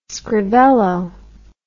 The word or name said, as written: scrivello